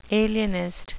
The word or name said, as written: alienist